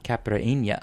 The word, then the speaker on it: Caipirinha: Caipirinha.